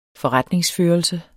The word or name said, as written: forretningsførelse